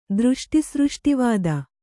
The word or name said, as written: ದೃಷ್ಟಿಸೃಷ್ಟಿವಾದ